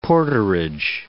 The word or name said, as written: porterage